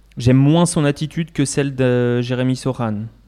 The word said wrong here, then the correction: Sochan: Sochan, mhm. pour ceux qui veulent prononcer à la française.